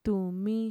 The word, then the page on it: tumi: Mixtec